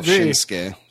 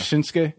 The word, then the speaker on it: Shinsuke: I wonder if that's the proper pronunciation of his name, Shinsuke. Because there was a wrestler in WCW called Kensuke Sasaki. And it was kind of spelled similarly. – I think that they were probably just mispronouncing it back then. I mean, he calls himself Shinsuke. – So. Yeah. Oh, he does? It is Shinsuke? – Yeah.